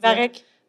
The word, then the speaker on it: Varech: l'as prononcé Varet, Moi, tantôt, je disais. Varec. – Varec, OK. Ouais, tu l'as peut-être pas entendu, mais ouais.